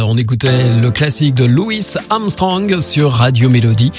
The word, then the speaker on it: Louis: Enfin, il paraît qu'il préférait qu'on l'appelle Louis à la française. Oui, parce que nous, en France, on prononce pas le S. Et il est arrivé qu'il sorte des disques avec un E à la place du S pour être sûr qu'on prononce son nom à la française.